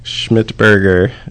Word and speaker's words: Schmidtberger: Schmidtberger.